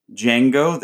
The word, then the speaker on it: Django: Barclay, aka Django, the D is silent, and the second best exotic Marigold Hotel.